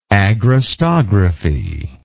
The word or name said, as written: agrostography